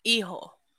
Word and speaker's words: hijo: El Hijo del Vikingo. That's I'm working on the Duolingo. 135 day streak. – Hijo. – Good job. Oh, it's the G with the H. – We don't pronounce the H.